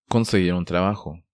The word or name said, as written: Conseguir un trabajo